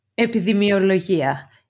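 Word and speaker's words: Epidemiologia (επιδημιολογία): How is it pronounced in Greek? – Epidemiologia. Epidemia. Epidemia means an epidemic.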